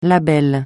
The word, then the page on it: label: francais